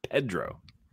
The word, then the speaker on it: Pedro: I heard him read his own name out loud, and he pronounced his own name as Pedro.